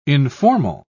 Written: informal

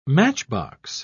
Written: matchbox

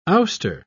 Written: ouster

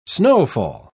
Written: snowfall

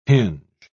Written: hinge

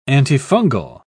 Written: antifungal

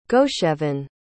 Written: gosheven

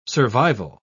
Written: survival

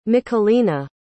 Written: michelina

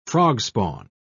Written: frogspawn

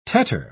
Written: tetter

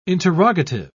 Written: interrogative

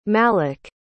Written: malak

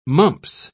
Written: mumps

